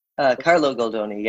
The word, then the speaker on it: Carlo Goldoni: His name was Carlos Goldoni. Am I pronouncing that right? – Carlo Goldoni, yeah.